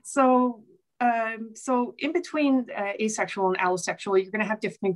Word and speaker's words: allosexual: And on the other side, you have allosexual, which is just regular, plain old sexuality in all of its forms.